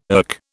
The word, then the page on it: erk: British English